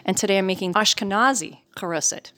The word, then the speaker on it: haroset: And mine is a more traditional Eastern European haroset, which is just apples, walnuts, wine, and I use nutmeg. But some people use cinnamon, but I like to use a little nutmeg. – Haroset is how it's pronounced, and it is a Hebrew word meaning clay.